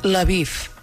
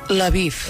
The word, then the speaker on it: Lviv: O sigui que hem de posar una vocal aquí gairebé, exacte. – Si no... – Exacte. I la pronúncia d'antena, doncs hem fet això, hem posat una L, una neutra i Lviv, perquè així el redactor ho llegeix bé.